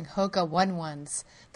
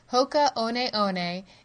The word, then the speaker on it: Hoka One One: Hoka One One is a Maori phrase which translates into time to fly. It is generally how our company name is pronounced. However, Hoka 1-1 is also a pronunciation used within the U.S.